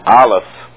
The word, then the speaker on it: Aleph: And this one is Aleph, is the way it's pronounced.